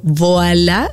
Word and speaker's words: Voilà: Voilà, así como se pronuncia, Voilà RD.